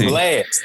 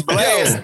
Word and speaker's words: Blxst: But Blist, it's BLXST. – It's just Blast. It's Blast. – It's Blast? – Yeah, it's Blast, bro.